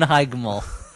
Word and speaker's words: Heigl: That's how you pronounce that? – I just pronounced hard G's as Catherine Heigl.